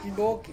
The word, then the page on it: piboki: Ansus